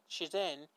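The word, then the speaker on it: Chichen: One of the most famous ancient spring equinox celebration celebrations took place at at goddamn i calling it chicken now. Chizian, chicken not chicken, like Chizen, I'll spell it.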